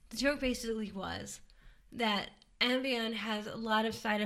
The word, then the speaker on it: Ambien: I know I say this wrong. Ambien. Ambien. I'm sorry. It's Ambien, not Ambien.